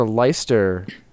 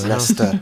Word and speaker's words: Leicester: There's a Leicester Road. – Near your house. – Leicester. – Right by my house. No big deal. And it is spelled Leicester. Weird spelling. – Leicester.